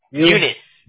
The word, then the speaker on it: Eunice: Eunice.